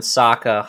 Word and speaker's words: Sokka: In the dialogue between Han and Sokka, Han pronounces Sokka's name incorrectly by elongating the O, which is the Asian pronunciation and how it's pronounced in the film The Last Airbender with Sokka. That's how it's actually pronounced.